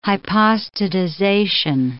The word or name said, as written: hypostatization